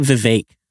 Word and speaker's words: Vivek: He says it Vivek. Vivek Ramaswamy. – That is how you say it.